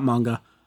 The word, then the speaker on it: manga: I need to carry on reading that manga. – Did I pronounce it right this time? Did I? Manga. I did it. – You did it right.